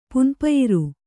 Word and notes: ಪುನ್ಪಯಿರು: Kannada

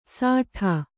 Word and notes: ಸಾಠಾ: sāṭhā

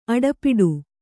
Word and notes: ಅಡೆಪಟ್ಟಿ: Kannada

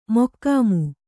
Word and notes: ಮೊಖಾಮು: mokhāmu

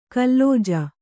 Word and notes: ಕುಲಜ: kulaja

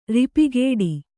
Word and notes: ರೂಪುಗೆಡು: Kannada

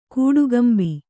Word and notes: ಕಟ್ಟುಕಂಬ: kaṭṭukamba

